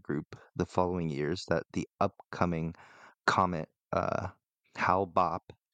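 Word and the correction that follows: Hale-Bopp: I'm pretty sure I pronounced that wrong even after looking up. – I think it's Hail Bopp. – Hail Bopp. – I think it's Hail Bopp if I remember.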